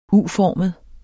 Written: U-formet

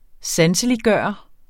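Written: sanseliggørende

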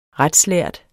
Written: retslærd

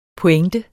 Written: pointe

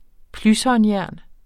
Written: plyshåndjern

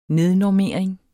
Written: nednormering

